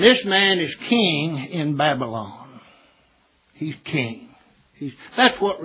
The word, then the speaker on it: Babylon: He was the king of Babylon. I love the way Tim James pronounced it. He pronounces that baby land. So that's what it is.